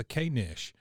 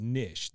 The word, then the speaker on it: knish: And so Luann asks, what's a K-Nish? And Peggy, of course, being well-informed and a worldly lady, says, honey, it's pronounced Nish. The K is silent, and I have no idea.